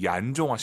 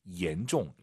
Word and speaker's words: 严重: 혹시 지금 마음속으로 얀종 하신 분 계십니까? 중국어의 얀이란 발음은 없어요. Y를 I로 바꾸세요. IAN, EN입니다. 실제로 한글 이로 시작하는 발음이에요. 옌이 아니라 옌, 옌종.